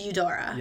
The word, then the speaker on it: Eudora: It's pronounced Eudora.